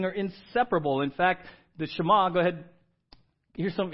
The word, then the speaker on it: Shema: Everyone say Shema. Right? Shema. That's how it's pronounced. No E in there. It's just Shema.